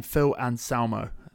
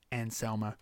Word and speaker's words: Anselmo: and I always forget how to say his his name, Phil Anselmo. How you, I think it's Anselmo.